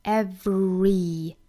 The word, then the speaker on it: everything: 那我们首先来看单词的发音，那第一个单词 everything, 大家就遇到难点了，我们来看这个单词要怎么发 everything, everything, 我们一定要注意这里面有一个小小的 v 的发音，一定要呢上齿咬一下下嘴唇啊，形成一个 v v 的发音。 Everything. 那 v 发完之后呢，有一个 re 的发音。 Everything.